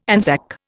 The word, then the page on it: Anzac: Indian